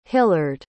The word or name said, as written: hillard